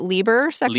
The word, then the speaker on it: Liber sexus perversus: The term for the guys, that particular fetish is, let me tell me if I'm pronouncing this right. – Okay. – Liber sexus perversus. – I think that's totally acceptable. We always said it Liber sexus.